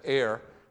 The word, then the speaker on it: err: It's pronounced you are, just as a word for you here instead of err. There, it looks like error without the O-R, but that's a little vocabulary word for you today.